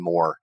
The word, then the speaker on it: Mohr: And my last name is spelled M-O-H-R. So Brian Moore.